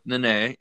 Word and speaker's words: Nene: Yep, Nene.